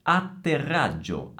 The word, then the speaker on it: atterraggio: La doppia T e la doppia G si pronunciano facendo una piccola pausa prima di pronunciare queste consonanti, mentre la doppia R si pronuncia allungando il suono della consonante. E allora, attenzione, atterraggio. Vedete? Due pause prima della T e della G e R, prolungare il suono della R.